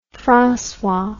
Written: francois

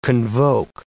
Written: convoke

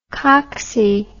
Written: cocksy

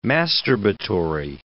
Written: masturbatory

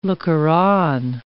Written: looker-on

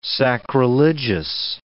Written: sacrilegious

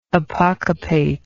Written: apocopation

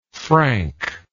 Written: franker

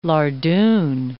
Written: lardoon

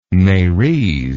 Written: merese

